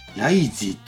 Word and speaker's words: RIIZE: で、これがライジーって読むの、 RIIZE。